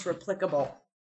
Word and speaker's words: replicable: No, but if it's replicatable evidence, then yes. – Evidence that was financed by the Illuminati. – Excuse me. – Oh, God. – the correct pronunciation is replicable.